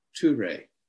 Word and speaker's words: Ture: And then we have Ture, T long U R E, Ture, which is the granter of all aims, her outstretched hand and her hand to the heart, which is a blessing mudra.